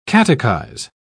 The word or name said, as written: catechize